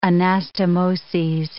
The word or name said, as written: anastomoses